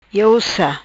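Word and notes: Iou Sa: Patuá

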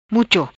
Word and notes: Mucho: Patuá

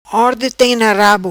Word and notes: Orde Tem Na Rabo: patua

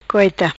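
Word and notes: Goita: patua

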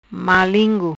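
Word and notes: Malingu: patua

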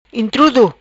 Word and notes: Intrudo: Patuá

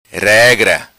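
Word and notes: Regra: patua

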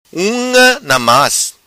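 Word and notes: Unga Namas: Macanese Patuá

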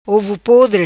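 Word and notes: Ovopodre: patua

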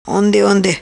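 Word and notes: Onde Onde: patua

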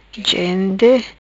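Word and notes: Gente: patua

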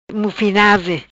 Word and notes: Mufinaze: Patuá

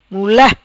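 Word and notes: Mula: patua

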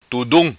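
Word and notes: Tudum: Macanese Patuá

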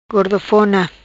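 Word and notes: Gordofona: patua

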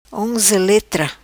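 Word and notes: Onzeletra: patua

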